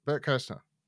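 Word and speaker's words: Bert Kreischer: Bert Kirshner? Yeah, that's it. Bert Kirshner.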